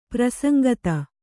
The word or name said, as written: ಪ್ರಸಂಗತ